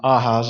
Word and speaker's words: Ahaz: This was spoken originally to King Ahaz, actually Ahaz is how they would have pronounced it,